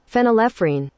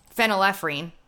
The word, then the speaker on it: phenylephrine: Phenylephrine. – Phenylephrine.